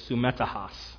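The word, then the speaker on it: sumetahas (συμμέτοχος): That's because the Greek word, and I've got a pronunciation help here, sumetahas.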